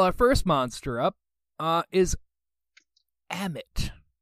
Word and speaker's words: Ammit: The first M is silent. It's Ammit.